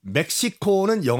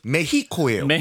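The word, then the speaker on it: México: 일단 발음 정정이요. 멕시코는 영어식 발음이고 그 현지 발음은 메히코예요. – 메히코. 오늘 그럼 메히코로 가나요? – 메히코. – 네. – 왜냐하면 그 스페니쉬에서 X는 히읗 H로 발음합니다.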